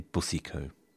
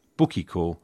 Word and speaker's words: Boucicault: I say Boucicault. because I'm told, gentle listener, that I've had the pronunciation Bukikor wrong all this time.